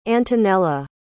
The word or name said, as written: antonella